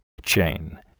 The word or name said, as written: chain